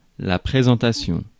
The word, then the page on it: présentation: France; [pʁe.zɑ̃.ta.sjɔ̃]; /pre.zɑ̃.ta.sjɔ̃/